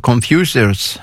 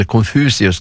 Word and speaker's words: Confucius: Då finns det ett ordspråk ifrån flera hundra år före Kristus, en man som heter Confucius, eller Confucius ska det väl uttalas.